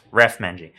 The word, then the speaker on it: Raf Manji: The Opportunities Party, also known as TOP, has appointed its new leader, Raf Manji. Am I pronouncing that right, Raf? – Raf Manji.